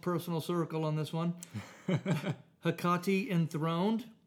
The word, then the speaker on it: Hecate: I've been calling it Hecate Enthroned for quite some time. Great question. Doing some research before the podcast. It is, in fact, Hecate.